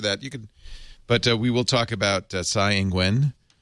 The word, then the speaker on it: Tsai Ing-wen: Say it again. – Tsai Ing-wen. – Tsai Ing-wen. – Ing-wen. – Ing-wen. – There you go. That's better.